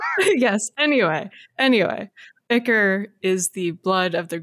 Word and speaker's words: ichor: and I was thinking Ichor or Ichor, depending on how you pronounce it. – Ichor. It's 100% Ichor.